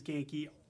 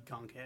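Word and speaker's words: Ganke: Ultimate Comics Genki is Genki. Marvel Universe Genki can be Gon K, if he's ever introduced.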